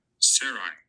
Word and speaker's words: Sarai: Sarai.